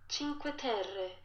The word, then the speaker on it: Cinque Terre: Cinque Terre, Italian.